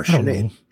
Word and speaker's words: Sinead: Sinead O'Connor. – Is that how you pronounce it? – Yeah. – How would I thought you? It was Sinead or No, Sinead.